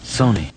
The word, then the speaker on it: Sony: That company is spelled S-O-N-Y. – What is it, Sony or Sony? Sony, yeah. Yes, right, yes. – Sony. – What is it? – Sony, like bony. Bony. Bony, Sony. Just feel your elbow when you're in doubt. – Okay. – Bony. – Sony 34.